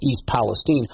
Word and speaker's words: Palestine: I mean, basically, when we are talking about this situation in East Palestine, Ohio, and that's how they pronounce it, Palestine, not Palestine. It's apparently pronounced East Palestine.